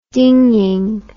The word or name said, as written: dinging